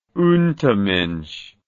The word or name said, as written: untermensch